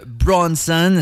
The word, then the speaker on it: BRXNSXN: On allait en faire un tour un peu dans l'ouest de la province, dans le coin de Gatineau, avec une collaboration de Bronson. Ça s'écrit B-R-X-N-S-X-N. On remplace Léo par des X, finalement. Collaboration avec un gars qui s'appelle Straz, S-T-R-A-Z-Z.